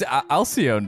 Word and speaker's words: Alcyone: He just dropped a new track called Alcone. Is that how you say it? – I think it's Alcyone, dude. – Alcyone? Oh, Jesus, it probably is. Alcyone. Come on. There we go.